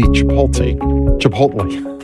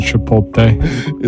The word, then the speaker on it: Chipotle: People on the East Coast don't say that. I just mispronounced it. – So, another comedian, Sebastian Maniscalco, likes to pronounce it Chipotle.